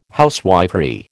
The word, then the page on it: housewifery: American English